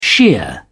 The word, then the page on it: shear: British English